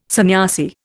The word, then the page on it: sanyasi: Indian